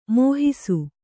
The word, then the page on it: ಮೋಹಿಸು: mōhisu